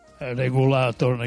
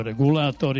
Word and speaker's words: regulator: Znači, mislim, to je, dakle, ne informator, nego informator, ne regulator, nego regulator i tako dalje.